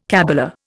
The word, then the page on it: cabala: Indian